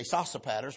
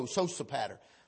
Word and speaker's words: Sosipater: I want to say Sosipater, but pronunciation mark says it's this O is a hard O, Sosipater.